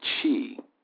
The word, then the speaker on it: Qi: And then we have the Qi, which is the second treasure. It is pronounced C-H-E-E, and that is translated as vitality.